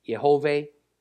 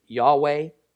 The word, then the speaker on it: Yehovah: Now, it won't be until the time of Moses that God actually divulges his formal and his personal name, Yud-Heh-Vav-Heh, which I pronounce as Yehovah, many pronounce as Yahweh.